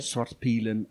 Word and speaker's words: Svartpilen: Svartpilen and Witpilen. – Thank you for the correction.